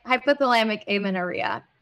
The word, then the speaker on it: hypothalamic amenorrhea: hypothalamic amenorrhea.